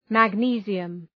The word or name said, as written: magnesium